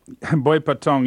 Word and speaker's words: Boipatong: This was in Boipatang. Am I saying that right? – Boipatong, yes.